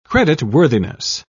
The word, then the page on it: creditworthiness: British English